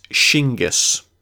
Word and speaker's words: Shingas: He does have some family ties, but he sets himself apart as a leader in battle, well respected amongst the western Delaware, known as Shingas. We would spell that typically S-H-I-N-G-A-S, Shingas.